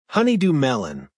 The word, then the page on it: honeydew melon: American English